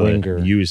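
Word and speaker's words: Wenguer: I was telling my podcast guest today, Adam Wenger, Wengauer, I'm always mispronouncing your name. You corrected me, though. I think I've been doing it wrong for like 4 years. It's W-E-N-G-E-U-R. – G-U-E-R. – G-U-E-R. You say Wenger. – So Wenger.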